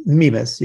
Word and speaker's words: Mimas: moon Mimas, or Mimas, well, how do you pronounce it? – Mimas, yes.